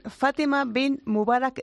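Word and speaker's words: Fatima Bint Mubarak: Espérate que lo digo bien. Fátima Bin Mubarak Ladies Open. Lo he dicho bien, ¿no? – Sí, exacto.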